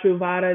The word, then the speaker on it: Truvada: But you talked about a medication called PrEP or Ultra, or let me make sure I'm saying it right, Ultra Bravada, right? – It's PrEP or Truvada.